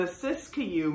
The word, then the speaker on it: Siskiyou: camping supplies, they set out for the Rogue River National Forest Campground, which is located in a remote area off of the, pardon my pronunciation, the Siskiyou Mountains in Oregon. Maybe that's right? – Yep, I believe it.